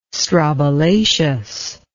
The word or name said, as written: strobilaceous